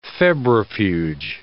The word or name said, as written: febrifuge